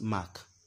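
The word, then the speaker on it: Mc: Now, another one that looks like it is M-C or M-E-C, pronounced Mac, okay?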